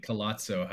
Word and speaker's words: Collazo: I've had only a few people have called me Collazo, how you guys pronounce it, Palazzo.